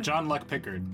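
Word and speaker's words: Jean-Luc Picard: John Luc Picard, yes. – John Luc Picard.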